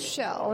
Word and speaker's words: Kjell: Well, and to finish that one part is that we have an astronaut on board right now who's from Europe, and his name is Shell, and it's spelled K-J-E-L-L because it's a type Scandinavian name.